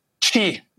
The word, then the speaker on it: Qi: Qi. It's qi. It's like the energy in Chinese medicine.